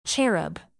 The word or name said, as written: cherub